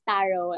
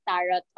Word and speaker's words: tarot: Yeah, it's tarot. It's silent T. Yeah. – Okay. – Learn something new every day. – It's okay. I used to read it as tarot also, like even like before I enrolled myself in the course, and then I found out that, oh, it's tarot.